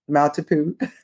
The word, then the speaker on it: Maltipoo: Did I say that right? Maltipoo. – yes.